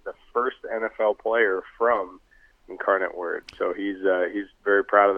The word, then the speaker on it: Incarnate Word: And how about Cole Wick out of Incarnate Word, is that how you say it? Incarnate Word out of Texas.